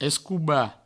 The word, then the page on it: escobar: provençal; escouba